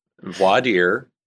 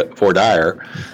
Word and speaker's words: voir dire: voir dire, if you're pronouncing French, or as we a lot of times we'll say in the St. Louis area, voir dire, is essentially the examination of potential jurors to see if they're proper for a particular case.